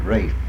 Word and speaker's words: Ralph: R-A-L-P-H is? – Ralph. English, Britishers pronounce it Rafe. – Is there a family around here named C-O-O-P-E-R? – Cooper.